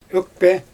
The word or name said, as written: ökpe